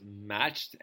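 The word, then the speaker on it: Matsch: So next up is a new band called I believe it's called pronounced Matched, and it's M A T S C H, and the song's called Five Miles Away.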